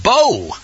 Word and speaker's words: Bo: But Bo kind of easily rhymes with go, so that's probably the way most folks that are just learning a little bit of Hebrew can remember it.